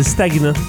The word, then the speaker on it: stagnant: Elle m'a appris que le mot stagnant, le GN se prononce gne. C'est stagnant.